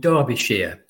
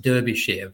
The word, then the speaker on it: Derbyshire: He was brought up in a county called Derbyshire. If you would look at it, you'd probably think it's pronounced Derbyshire, but Derbyshire.